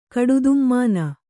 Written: ಕಡುದುಮ್ಮಾನ

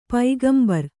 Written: ಪೈಗಂಬರ

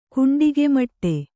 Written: ಕುಂಡಿಗೆಮಟ್ಟೆ

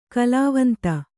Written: ಕಾಲ್ವಂತ

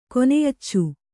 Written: ಕೊನೆಯಚ್ಚು

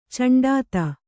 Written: ಚಂಡಾತ

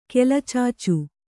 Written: ಕೆಲಚಾಚು

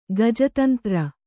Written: ಗಜತಂತ್ರ

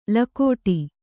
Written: ಲಗುಡಿ

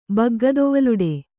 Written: ಬಗ್ಗದೊವಲುಡೆ

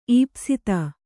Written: ಈಪ್ಸಿತ

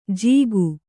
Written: ಜೀಕು

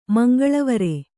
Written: ಮಂಗಳವರೆ

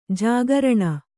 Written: ಜಾಗರಣ

